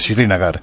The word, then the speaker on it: Srinagar: Es que la pronunciación de la capital es muy difícil. Es Srinagar.